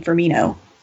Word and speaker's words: Firmino: I have this feeling that we're still going to put out a pretty strong team, including Firmino. Is that okay? Did I pronounce that correctly? – Yeah, very, very well done.